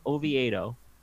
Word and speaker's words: Oviedo: And I like pronouncing it that way because that's exactly how it's spelled. And it's named for the Spanish city of Oviedo. So it turns out Oviedo and the chickens are wrong.